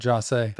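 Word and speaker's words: José: Is it Jose? – Jose. Jaw is what a lot of them would say, but Jose.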